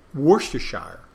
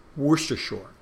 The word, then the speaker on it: Worcestershire: Worcestershire. Some people call it, because you have Worcester, Massachusetts, and they don't pronounce the R, you know.